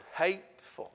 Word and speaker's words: hateful: And it's a three-syllable word, hateful. You know, if you say it right.